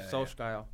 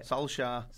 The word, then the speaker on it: Solskjaer: So, obviously, everyone was saying this is United's first test in terms of Solskjaer. Yeah. – Who? – This is what I told you, pronunciations. How do you say it? – Solskjaer. – Solskjaer.